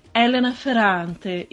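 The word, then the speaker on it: Elena Ferrante: porque em italiano Helena Ferrante se pronuncia Helena Ferrante.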